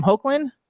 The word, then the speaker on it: Hoechlin: Hoechlin.